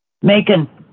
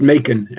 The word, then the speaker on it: Macon: Macon. – Yeah. – Say again? – Pronounced Macon, M-A-C-O-N, pronounced Macon.